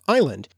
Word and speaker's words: island: In another example, the s was added to island because of the mistaken belief that it was connected to the Latin derived word isle, from Latin insula,